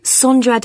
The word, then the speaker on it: Sondra: Sondra Da Costa. – Is that Sandra? – No, it's spelt with an O. – So that's S-O-N-D-R-A.